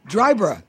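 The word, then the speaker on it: Dryburgh: No, not quite, but not far off. – Oh. – Dryberg.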